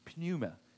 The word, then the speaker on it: pneuma: Most of the time, when the Bible says the Holy Spirit, it uses the Greek words hagios, pneuma. I'm not sure you pronounce it. Pneuma. P-N-E-U-M-A.